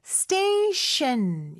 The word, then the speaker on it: station: 자, station 할 때는요. 앞에 T 발음은 쌍디귿 발음으로 된소리로 해주시고요. 뒤에 T 발음은 SH 발음이죠. 그래서 station 이렇게 발음하시면 돼요.